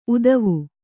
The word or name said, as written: ಊತ್ವ